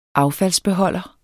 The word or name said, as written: affaldsbeholder